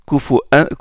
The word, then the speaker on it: كفوا: Hamza, quand il prononce Kufu'an, il prononce Kufu'an Ahad comme Ya'qub et Khalaf. Mais il marque une pause entre le tanwin et la hamza qui la suit.